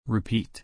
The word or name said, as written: repeat